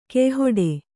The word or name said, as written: ಕೆಯ್ಹೊಡೆ